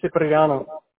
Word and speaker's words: Cipriano: It's pronounced Cipriano. – Okay. Can you spell that for me? – sure. C-I-P.